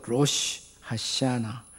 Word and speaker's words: 로시 하샤나: 로시 하샤나. 발음이 어렵지만 한번 따라서 해봐요. 로쉬, 하샤나.